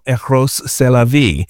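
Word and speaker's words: Rrose Sélavy: And Marcel Duchamp had a trans version of himself called Eros c'est la vie, which, right, in French, that means you'd say Eros, that's life, right? But it's spelled Eros Elavy, right? So the great pun, you know.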